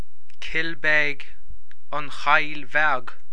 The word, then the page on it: An Chill Bheag: Irish